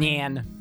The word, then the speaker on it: Nyan: No, it's Nya, as in like the sound a cat makes.